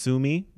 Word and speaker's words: Suomi: Which is pronounced Suomi, for those of you who don't know that.